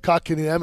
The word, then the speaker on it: Kotkaniemi: Help Kotkaniemi.